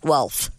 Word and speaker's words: Guelph: Yeah, I said Guelph. You don't listen to anything. – Guelph? – Nope. – Yes. – G-U-L-P-H. – It's pronounced G-W-E-L-P-H. Guelph.